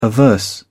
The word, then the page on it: averse: British English